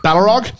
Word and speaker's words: Balrog: Balrog.